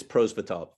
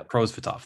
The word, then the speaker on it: Prosvetov: And that's goalie Ivan Prosvetsov. How do you pronounce that correctly? – It's Prosvetov. Yeah. – Prosvetov. Thank you.